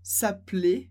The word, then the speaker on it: s'appeler: Perceba que a pronúncia do primeiro é mais fechada, s'appeler, e a segunda bem aberto, m'appelle, m'appelle.